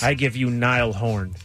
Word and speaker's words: Niall Horan: It's Niall Horan.